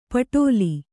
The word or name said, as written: ಪೆಟಲ